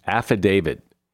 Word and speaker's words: affidavit: Affidavit with a D on the end. There's a T on the end, and it should be pronounced affidavit.